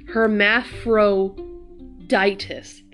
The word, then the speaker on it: Hermaphroditus: Hermaphroditus, there you go.